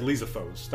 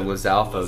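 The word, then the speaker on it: Lizalfos: How do you guys pronounce this? I always said Lysophos. That's how I've always pronounced it. – Oh, Lysalfos. – Lysalfos. Yeah, okay. Yeah, and I think I've adopted that eventually.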